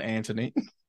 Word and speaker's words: Antoine: So down here, we pronounce Antoine Anthony.